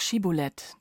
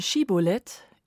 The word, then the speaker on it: Schibbolet: Denn Schibulet ist im Alten Testament das Wort, das während der israelitischen Stammeskriege für die Ephraimiten zum fatalen Prüfstein wird. Falsch ausgesprochen, weil sie SCH nur als S sprechen können, verraten sie sich bei ihren Feinden und werden getötet.